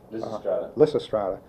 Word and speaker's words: Lysistrata: 1930, I may pronounce this wrong, Lysistrata? – Lysistrata.